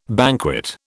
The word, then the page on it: banquet: British English